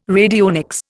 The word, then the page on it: radionics: Indian